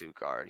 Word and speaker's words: Utgard: the trip to Utgard, however it's called. Yeah, it's Utgard. He said it right here. Or no, right here. Utgard.